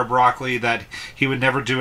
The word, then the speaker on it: Broccoli: There are numerous James Bond references throughout the movie, resulting in a rumor that Albert R. Meraki, um, Broccoli is what I'm going to pronounce his name. – Oh yeah, Broccoli.